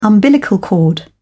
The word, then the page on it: umbilical cord: British English